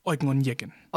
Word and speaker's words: Eugen Onegin: Eugen Onegin. – Eugen. Aha. Ich kann das nicht aussprechen. – Eugen Onegin.